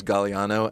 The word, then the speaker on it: Gagliano: My last name is Gagliano and has a silent G in it, and more people mispronounce his name because it looks like a trick.